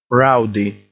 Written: rowdy

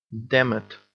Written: damn it

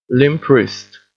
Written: limp wrist

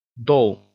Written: doll

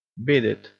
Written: beat it

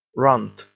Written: runt

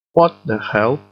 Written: what the hell

